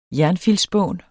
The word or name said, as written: jernfilspån